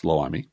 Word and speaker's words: Loami: In other local news, voters in Loami, am I saying that right? – Yes, Loami.